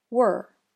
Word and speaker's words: we're: Were begins with a W consonant sound. It then has a schwa plus R sound combination, just like the vowel sound, as in her, were, her, were. So even though it's written as if it would have two different syllables, it's just the one sound.